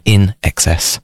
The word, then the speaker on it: INXS: So INXS, it's INXS, which is supposed to be pronounced in excess.